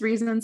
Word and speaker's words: reason: In Elizabethan times, the EA in reason would have been pronounced like the AI in raising, yielding a dirty joke.